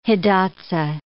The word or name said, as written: Hidatsa